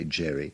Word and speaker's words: Gerry: Well, in 1811, a bill came before Governor Gerry, people pronounced it that way, Gerry, which rather drastically redrew the congressional districts of Massachusetts to favor the Democrats and rob the opposition of a seat or two.